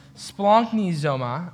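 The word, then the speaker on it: splanknizoma (σπλαγχνίζομαι): In fact, in the English, you really can't even translate it correctly, but the actual word, I'm gonna write it out because it's something else, is pronounced splanknizoma, which is this Greek word, and it is incredibly powerful.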